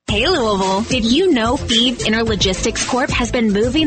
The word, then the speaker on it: Fives: Feeb is spelled F-I-V-E-S, like the number, but pronounced Feeb.